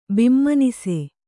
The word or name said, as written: ಬಿಮ್ಮನಸೆ